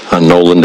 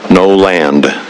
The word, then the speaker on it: Noland: My name is Representative Mark Noland out of House District Number 10. Noland is spelled Noland, N-O-L-A-N-D.